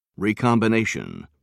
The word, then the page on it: recombination: British English